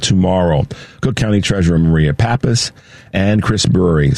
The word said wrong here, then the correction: Burry: Sometimes, we get that name wrong, but it's Chris Burry, former TV news person and recent retired DePaul University journalism professor.